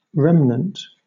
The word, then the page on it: remnant: British English